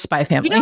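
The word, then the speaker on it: Spy × Family: And so also, I did learn recently that if there's an X, it's usually not pronounced. So like Hunter X Hunter is actually Hunter Hunter. So I think it's just Spy Family.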